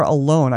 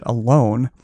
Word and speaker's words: Alon: Okay, so the next one is Alon or Alone. I knew a guy one time. It's A-L-O-N, and he pronounced it Alone, but it could be Alon.